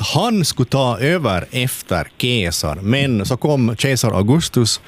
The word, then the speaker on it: Caesar: Julius Caesar eller Julius Caesar som det ska uttalas egentligen. Jag har studerat latin, så det här är det enda jag minns från mina latinstudier. – Så vi borde äta Caesarsallad. – Vi borde äta Caesarsallad. C ska uttalas K.